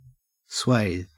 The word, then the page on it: swathe: Australian English